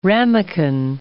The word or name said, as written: ramequin